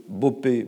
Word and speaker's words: Bopé: Aroé, ça s'écrit A-R-O-E, Bopé, B-O-P-E.